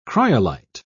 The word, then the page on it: cryolite: British English